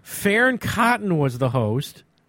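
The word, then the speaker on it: Fearne Cotton: Farron Cotton. I'm saying it right. – Right. They say it like Farron.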